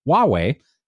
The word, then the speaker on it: Huawei: Look, I even got the name right. – Look, I was going to say, look at you. You said Huawei right, finally. – And I'm buzzed. As part of the trade war with China and all repercussions, it might. Maybe I have to be drunk to say it right.